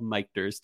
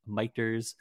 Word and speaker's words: Michter's: And yes, if you're wondering, we are mispronouncing Michter's. It is called Michter's technically.